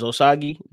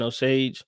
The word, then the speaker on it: Osage: I think that's how you pronounce it. I thought it was Osage, but they were saying Osage, so we're gonna roll with that.